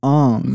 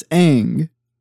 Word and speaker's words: Aang: Like the bald kid, his name isn't Aang. – Who's the Avatar. – Yeah, it's Aang.